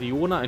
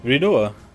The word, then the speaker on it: Rinoa: Selfie ist einfach nur Zufallsfaktor mit Rinoa oder Riona. Entschuldigt, jeder Fan. Ich kann diesen Namen nicht richtig aussprechen, weil ich ihn beim allerersten Mal falsch gelesen habe. – Rinoa.